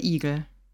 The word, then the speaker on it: Igel: es ist mir nicht mal aufgefallen, dass Igel lang ist, lang ausgesprochen wird. Also. – Also wenn ich es jetzt nicht so überdeutlich aussprechen würde, klänge das so. Der Hase und der Igel.